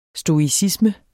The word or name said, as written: stoicisme